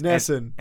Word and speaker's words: NESN: That you're mispronouncing it. – It's Nesson. – NESN. – Nesson.